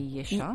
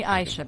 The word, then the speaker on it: Aisha: Bonjour, studio 1, Talal Chahri, Oman FM, And you know, that was one thing that I hated in France, because they can't pronounce H. They used to call me Yesha. – Yesha. Mm-hmm. – And I'd be like, no, just don't call me Yesha. So I was like, call me Aisha, please.